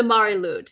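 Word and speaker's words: Mari Lwyd: But one of the figureheads of the wassail, which was the name of this practice in Britain, across Britain, was the Mari Lude. And that's spelled Mariliwd.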